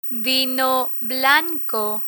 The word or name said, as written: vino blanco